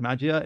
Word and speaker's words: Magia: And that was a project called Project Magia, M-A-G-I-A. Project Magia is how I'm going to pronounce it.